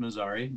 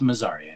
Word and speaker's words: Mazarei: Sorry, it's pronounced Mazarei.